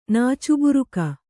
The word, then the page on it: ನಾಚುಬುರುಕಿ: nācuburuki